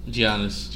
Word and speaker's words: Giannis: Giannis. – Giannis.